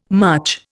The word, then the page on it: much: Indian